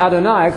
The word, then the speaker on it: Adonai: You all know about Adonai because it rhymes with El Shaddai.